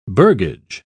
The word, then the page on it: burgage: British English